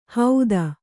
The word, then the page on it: ಹೌದ: Kannada